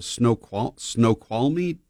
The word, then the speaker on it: Snoqualmie: Snoqualmie? You know, is that right? Okay, Tara's nodding her head.